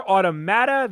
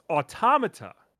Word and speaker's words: Automata: What, autom- uh, well, so I always thought it was Automata. – So did I. – But in in the trailer, they pronounce it as Automata.